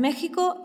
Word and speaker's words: México: Los mexicanos prefieren escribir México y Oaxaca, pero siempre pronunciarán México y Oaxaca.